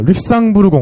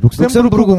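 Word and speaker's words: Luxembourg: 어, 릭상부르 공원. 그렇지. – 무슨 네. 발음이 룩셈부르 공원이 아니라 네. 아유, 그 발음이 후졌어. 그러니까 릭상부 릭상부르.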